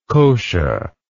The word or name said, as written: kosha